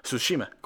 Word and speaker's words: Tsushima: ¿Se pronuncia la T? Es que empieza con T. Ghost of Tsushima, no, Tsushima, como sushi. Sí, yo creo que es Ghost of Tsushima, el fantasma de Tsushima.